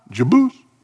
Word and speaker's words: Jebus: Jebus, Hebrew language often puts the accent on the last syllable, Elohim, Adonai, Jebus.